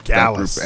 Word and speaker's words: Gallus: It's Pretty Deadly, Diamond Mine, Gulas, and I believe it's pronounced Gulas. – Gallus. – And Gallus, right. And I forgot it's Gallus.